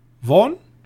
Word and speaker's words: Vaughn: Ein Horror, eine Horrorkomödie würde ich es mal nennen, äh, mit Caspian Newton und Vince Vaughn.